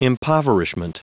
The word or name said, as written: impoverishment